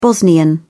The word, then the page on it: bosnian: British English